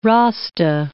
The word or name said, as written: Rasta